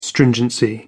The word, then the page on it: stringency: American English